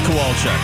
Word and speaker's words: Kowalczyk: And then you're not going to say anything until Ed Kwaszczuk. How do you say his last name? It's Ed something in this band. Until he starts yelling. – People of the soul. – It is Ed Kowalczyk.